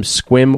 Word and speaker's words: Sequim: Ken Westby from Squim, Washington." And it is pronounced a squim, but it's spelled S-E-Q-U-I-M. But he went to the extra length of making sure I knew how to pronounce it.